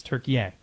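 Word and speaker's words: Türkiye: Is it Turkey, A-Y-E? Hold on a sec. That is how it's spelled. But they now say the pronunciation is Turkey.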